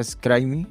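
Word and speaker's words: Scribe: Pronuncia Scrime.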